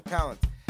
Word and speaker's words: Palance: Yeah, Palance.